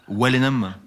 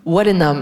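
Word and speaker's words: Walenhammes: On prononce comme ça Wallenham ? – Non, plutôt avec un ou wallon. Wallenham. – Wallenham, voilà. D'accord. Oui, je me dis, j'étais sûre qu'il y avait un petit. Donc, La nuit de Wallenham.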